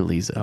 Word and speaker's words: Liza: All right, this first one comes from our girl, Well, how do you pronounce L-I-Z-A? Liza or Liza? – Oh, Liza.